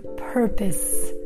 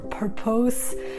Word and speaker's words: purpose: By the way, careful with the pronunciation of that word, purpose, okay? Sometimes students say propose or purpose, right? Just a friendly reminder, the pronunciation is purpose.